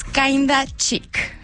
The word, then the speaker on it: Kinda Chic: En inglés se escribe kinda, es K-I-N-D-A, okay. Y chic, de la palabra que todos conocemos como chic, C-H-I-C. – Muy bien, kinda, kinda chic.